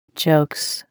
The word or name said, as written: jokes